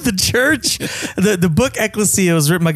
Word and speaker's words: Ecclesia: A lot of people pronounce it wrong. Mm-hmm. They say Ecclesia, but it's Ecclesia. Ecclesia.